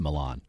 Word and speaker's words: Milan: I'm just stupefied that I mispronounced the city in Italy because he said Milan. I'm like, yeah, the city in Italy. And he says, it's Milan.